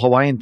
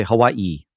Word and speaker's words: Hawaii: You even say it like normal Hawaiian people. You don't say Hawaii, you say Hawaii. – Hawaii, yeah, that is it's the Hawaiian pronunciation.